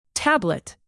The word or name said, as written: tablet